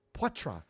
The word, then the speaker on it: Poitras: Poitras. Oh, you don't pronounce the S.